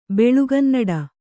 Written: ಬೆಳುಗನ್ನಡ

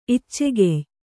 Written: ಇಚ್ಚೆಗೆಯ್

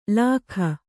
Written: ಲಾಖ

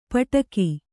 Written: ಪಟ್ಟುಕ

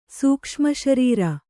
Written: ಸೂಕ್ಷ್ಮಶರೀರ

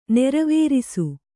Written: ನೆರವೇರಿಸು

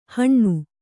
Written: ಹಣ್ಣು

